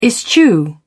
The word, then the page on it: eschew: British English